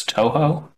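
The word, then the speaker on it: Touhou: 2, A Game of Clones, Tuhua Luna Nights, I think. I think you'd pronounce that as Toho.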